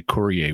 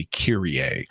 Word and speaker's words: Kyrie: You could use the word for Lord in place of Mr. And when you do that, it shifts from Kurios to Kurie, except in modern pronunciation, we don't say Kurie, we say Kyrie.